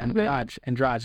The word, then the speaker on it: Andrade: Andrade, that's how to pronounce it.